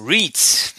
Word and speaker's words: REITs: REITs. REITs. REITs, genau, wird, wird REIT geschrieben, aber REITs, glaube ich, ausgesprochen.